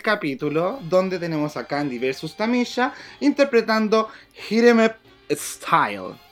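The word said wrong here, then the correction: Hit 'Em Up Style: Lo dije mal. Hit em up style.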